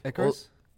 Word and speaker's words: Icarus: Icarus. I don't know how you pronounce that, but Icarus.